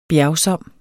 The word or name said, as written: bjergsom